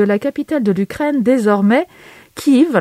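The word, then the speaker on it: Kyiv: Quand j'étais en classe de 6e, devant ma carte du continent européen, car Kiev est la graphie et la prononciation ukrainienne.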